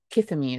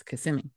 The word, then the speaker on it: Kissimmee: It's like, it's not Kissimmee, it's Kissimmee. Mm-hmm.